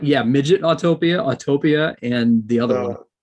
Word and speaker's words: Autopia: I think it's like your transportation rides, not Autopia. And also not pronounced Autotopia. I don't know why people do that either.